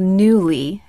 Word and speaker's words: Nuuly: Okay, so I did this box called Newly, and it's spelled Nuuly.